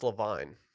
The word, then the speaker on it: Levine: It's pronounced Levine.